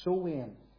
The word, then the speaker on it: Samhain: It's spelt Samhain, but that's not how it's pronounced. It's pronounced Sowain, S-O-W-I-N, because in the Gaelic words apparently are pronounced differently.